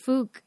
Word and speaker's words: Fuck: I was just going to say that on that documentary, there was a guy with the last name F-U-C-K from Brazil. Do you remember that guy? – No, I don't. – In Brazil, it's pronounced Fook, and he's, like, not in Brazil anymore.